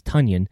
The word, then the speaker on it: Tonyan: I do believe that Robert Tonyan, because apparently he corrected the media and said that that's actually how to pronounce his last name is Tonyan.